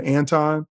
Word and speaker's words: Anton: But on the third time, if you're still calling me something other than Anton, then you're saying to me that you don't care enough about me to even pronounce my name right.